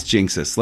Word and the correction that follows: Jixtus: Let me look at the spelling one last time here. Jixtus. There was no N. Jixtus.